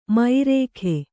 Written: ಮೈರೇಖೆ

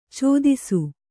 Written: ಛತ್ತೀಸ

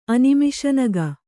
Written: ಅನಿಮಿಷನಗ

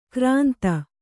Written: ಕೋರಿಂದೆ

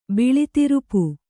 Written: ಬಿಳಿತಿರುಪು

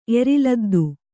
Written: ಎರಿಲದ್ದು

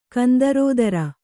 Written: ಕಂದರೋದರ

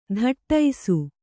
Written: ಥಟ್ಟೈಸು

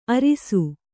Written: ಆರಿಸು

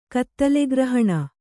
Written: ಕತ್ತಲೆಗ್ರಹಣ

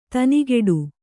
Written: ದನಿಗೊಡು

